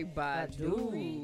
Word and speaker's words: Badu: It was just like, say Badu. – That's how you say it.